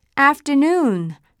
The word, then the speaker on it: afternoon: Afternoon Afternoon OO の部分を強く発音していたのが分かったかな？ Afternoon の after は何々より後の Noon は昼の 12 時。